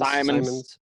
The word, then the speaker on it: Simons: And Anthony Simmons. – Simons.